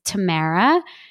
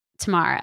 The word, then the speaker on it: Tamara: And I will also say, because Amber, you say my name with such an accent, it's Tamara versus Tamara.